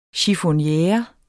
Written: chiffonniere

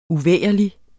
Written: uvægerligt

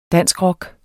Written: danskrock

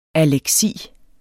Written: aleksi

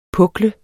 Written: puklet

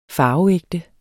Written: farveægte

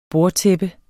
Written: bordtæppe

